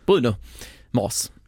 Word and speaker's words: Bruno Mars: Bruno Mars.